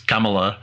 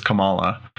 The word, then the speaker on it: Kamala: Well, and it's funny because in the trailers, they make somebody gets her name, pronounces it wrong and says Kamala. She's like, no, it's Kamala.